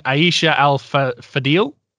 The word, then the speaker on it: Aisha Al-Fadil: And Aisha Al-Fadil. I don't know if I'm saying that right. – Yeah. Let's you said it right.